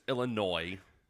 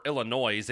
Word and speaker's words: Illinois: Otherwise known as Illinois. – Yeah. – Or Illinois, if you don't know how to pronounce it. – Man, so people put the hard S on that one.